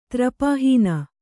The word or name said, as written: ತ್ರಪಾಹೀನ